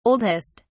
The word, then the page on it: oldest: British English